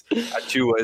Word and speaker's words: Achiuwa: Alphonse Precious Achiuwa. Is that how you pronounce his name, by the way? – That's how we had Alf pronouncing it the other day on the pod, yeah.